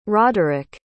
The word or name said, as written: roderick